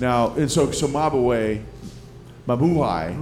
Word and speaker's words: Mabuhay: The Mabuhay Gardens was a restaurant. – Am I saying it wrong when I say Mabuhay? – Mabuhay. Which means long live.